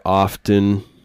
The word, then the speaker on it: often: So last week's episode, I want to do stuff like that more often. Often. I think the T is silent and often.